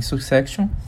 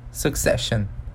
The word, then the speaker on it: Succession: Não sei se vocês que estão aí nos ouvindo assistem Succession, mas enfim, até falei errado, né? Succession, mas enfim.